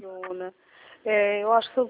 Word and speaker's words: Orizona: Orizona se escreve Orizona? – É Ourizona. – Orizona. Certo. Só mais um instante. Ourizona, é isso? – Isso.